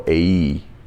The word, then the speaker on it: Ai: God now is instructing them to go take Ai. If you want to get Hebrew about it, it can be pronounced as well AE, but I'll say AI. It's easier way to remember it.